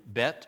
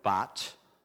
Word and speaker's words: Bet: When you get to Bet or Bat, depending on how you want to pronounce it, those eight verses then all begin with that letter, all the way through the 22 stanzas.